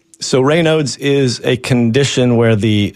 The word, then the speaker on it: Raynaud's: What can you tell me about Raynaud's, however it's pronounced, syndrome? – Raynaud's.